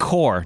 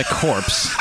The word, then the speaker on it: corps: The Marine Corps, not the corpse.